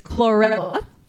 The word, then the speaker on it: chlorella: Well, first of all, I'm going to correct the pronunciation. It's chlorella is how you pronounce it.